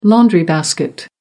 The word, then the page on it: laundry basket: American English